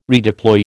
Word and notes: redeploy: American English

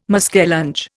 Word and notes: muskellunge: Indian

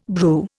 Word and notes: brew: Indian

